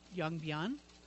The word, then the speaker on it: Yongbyon: Yongbyon. – Okay. – I am pronouncing that correctly, I'll have you know.